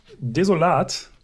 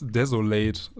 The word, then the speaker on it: Desolat: tatsächlich häufig äh, als desolate äh, doch angesprochen oder ausgesprochen worden. Warum, äh, weiß ich nicht, aber es passiert doch häufiger als die deutsche Ausdrucksweise.